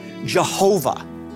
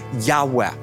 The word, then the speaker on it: Yahweh: Now, today, we hear different songs that are calling God Jehovah. And this is a mispronunciation of God's holy sacred name, a breathy Yahweh.